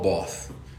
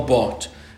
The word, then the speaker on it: Barth: And it is spelt with a TH at the end, but it's not Karl Barth. He's German, it's a hard T. Karl Barth.